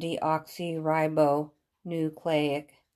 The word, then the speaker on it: deoxyribonucleic: It's pronounced deoxyribonucleic.